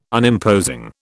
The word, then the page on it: Unimposing: British English